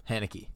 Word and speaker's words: Haneke: Haneke? Am I saying that right? – Michael Haneke. You're saying it right.